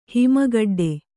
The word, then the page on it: ಹಿಮಗಡ್ಡೆ: Kannada